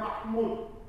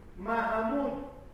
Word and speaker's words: Mahmud: El nombre verdadero es Mahamud. No se dice en Persia Mahmud. Mahamud.